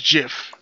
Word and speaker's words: GIF: It is pronounced GIF.